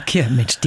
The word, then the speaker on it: Chiemsee: bin ich dann an Chiemsee gezogen. – Also das war Chiemsee, die Aussprache.